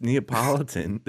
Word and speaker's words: Neapolitan: No, it's Neapolitan. There's no I after the T, Kevin.